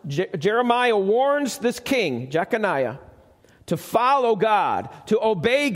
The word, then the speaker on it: Jeconiah: All right, and it's pronounced a couple different ways, but that's the basics of it. Jeconiah.